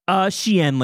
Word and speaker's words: Xi'an: Is it pronounced Xi'an? – Xi'an, like the province in China. That's Xi'an.